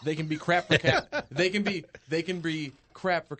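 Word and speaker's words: Kakko: So crap for Kako. – I think that's how it's K-A-K-K-O is how you pronounce the last name of the guy who's roundly projected as the second overall pick.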